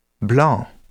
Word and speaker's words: blanc: Be aware that there are several common words in which final C is not pronounced. Bon, blanc, beurre, tabac.